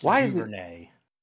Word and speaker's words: Duvernay: I guess DeVernay. – He didn't know Sterling Shepard was going to be out going into that game, though. – Oh, it's pronounced, wait, I take it back. – It's pronounced Duvernay.